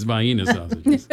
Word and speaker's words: vienna sausages: You didn't say it right earlier on. It's vienna sausages. I know that because I'm from this area.